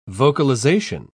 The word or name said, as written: vocalization